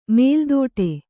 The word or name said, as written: ಮೊಲೆದೊಟ್ಟು